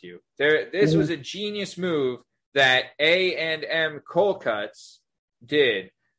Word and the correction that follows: A&N: Did I say it right, Pete? – A-N-N. – A-N-N. I get it wrong every time.